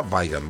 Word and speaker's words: Weigand: De arranque nomás, en Boca comunicó a Arrua Barrena que Marcelo Weigand, la pronunciación correcta, Weigand,